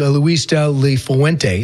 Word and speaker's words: Luis de la Fuente: He said as soon as he was done playing, he went to Spain to a coaching academy, and his coach was Luis de la Fuente.